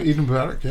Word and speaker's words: Eid Mubarak: I don't know how to say it. – Eid Mubarak, yeah.